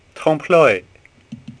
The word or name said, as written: TrompelO